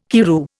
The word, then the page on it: Karroo: Indian